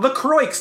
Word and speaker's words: La Croix: I don't know if I don't know if it's fucking Cajun or it's French, but L A C R O I X. That's La Croix. But people fucking want to be like, oh no, it's Lacroix, and I'm like, fucking just call it Lacroix then.